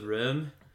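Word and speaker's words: Röhm: And if Angel were allowed to have sex, it'd be like, yeah, because Elizabeth Rome is hot. It's also, like, technically pronounced Elizabeth Rim. Rim, okay. Because of the umlaut over the O.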